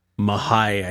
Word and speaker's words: mahi-mahi: I had some good, I got some mahi-mahi. Mahi-mahi. – Mahai, I think is how they say it in the islands. Mahai.